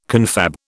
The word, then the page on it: confab: British English